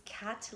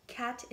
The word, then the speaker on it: Kat: The reason my spiritual name is Kat Lilayana Sun, Kat is spelled K-A-T.